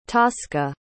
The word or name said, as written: tosca